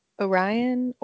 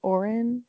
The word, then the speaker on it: Orin: Are we going with Orion? Orin? – Orin. – Okay, thank you. – Orin. – Why was this not in my words to look out for? – Because it's spelled phonetically already.